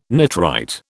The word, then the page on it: nitrite: British English